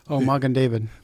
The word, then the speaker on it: Magen David: the Magadian. – Magen David.